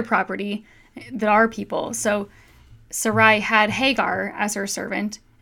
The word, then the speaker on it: Sarai: Yeah, if you read it, it says that Sarai? Is that how you pronounce that? – Yeah.